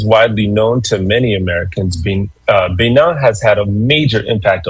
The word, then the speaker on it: Benin: But first, today we're headed to Benin, or Benin, as it's pronounced in French, a country in West Africa with deep history and powerful connection to the Black diaspora.